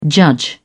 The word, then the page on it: judge: British English